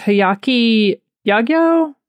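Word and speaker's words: Hyakki Yagyō: But it's Hayaki Yagyo. It's Hayakiyagy long O.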